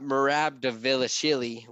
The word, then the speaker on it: Merab Dvalishvili: I think it maybe mispronounced that, but you got Merab Dvalishvili, which I know I pronounced correctly. – Yeah, that's right.